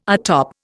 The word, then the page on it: atop: Indian